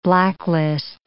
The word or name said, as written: blacklist